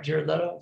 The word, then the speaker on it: Jared Leto: Jared Leto, is that right? – Yeah.